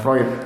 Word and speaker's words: Freud: Freud.